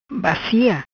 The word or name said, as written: Bacia